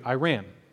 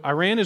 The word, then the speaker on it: Iran: You have to go to Iran. So Iran is where, or Iran. I know we pronounce it both ways here in East Texas.